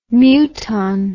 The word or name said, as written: muton